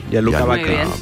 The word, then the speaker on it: Gianluca Vacchi: Entonces, ¿usted sabe quién es Gianluca Vacchi? ¿Lo he dicho bien, María José? – Sí. – Gianluca Vacchi.